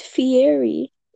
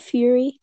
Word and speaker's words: Fieri: It's pronounced Fieri. It's not Fury.